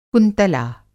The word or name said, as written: कुंतला